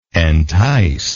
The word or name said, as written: enticingness